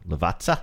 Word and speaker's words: Lavazza: Ooh, Lavazza? Is that how you say it? Yeah. Two Z's is a T.